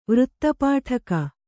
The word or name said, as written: ವೃತ್ತಪಾಠಕ